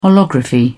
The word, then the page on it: holography: British English